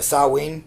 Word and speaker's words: Samhain: It's Samhain, the ancient Celtic festival of the dead. – If you're going to pronounce it like a Celt, yeah.